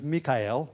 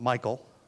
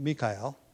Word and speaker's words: Mikael: Another is Mikael. We pronounce it Michael. All right, but Mikael.